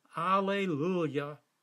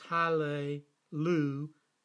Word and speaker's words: Hallelujah: Alleluia. Phonetically, in Hebrew, it is Hallelujah.